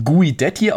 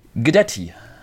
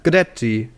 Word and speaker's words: Guidetti: Übrigens, Christian, dieser gute Mann, er wird nicht Guidetti ausgesprochen, auch nicht Guidetti, sondern wir wurden belehrt. Vielen herzlichen Dank an Twitter-User Das Dann, @das_dann3000. Vielen Dank für die Grüße, der uns gesagt hat, ein leicht stummes U und ein komplett stummes I. So wird der Mann richtig ausgesprochen. Also mehr so ein Guidetti.